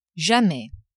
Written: jamais

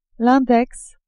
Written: index

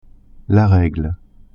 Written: regle

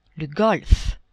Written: golfe

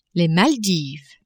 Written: Maldives

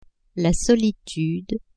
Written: solitude

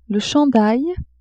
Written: chandail

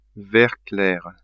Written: vert clair

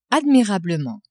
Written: admirablement

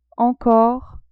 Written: encore

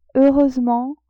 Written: heureusement

